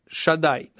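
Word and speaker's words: Шаддай: Это обращение Всевышнего к Якову, Якова Вину, что я Бог, который называется Бог Шадай, да? Это имя Всевышнего, да? Я его специально сейчас произношу, чтобы вы понимали, как оно правильно произносится, но на самом деле это аббревиатура, то, что Гемара здесь говорит.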